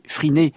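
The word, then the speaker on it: Phryné: On peut y voir notamment une statue de Pierre-Étienne Campagne, une statue qui a été réalisée pour la création de l'opéra Friné. Alors, ça s'écrit P-H-R-Y-N-E, Friné, créé en 1893.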